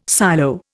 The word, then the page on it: silo: Indian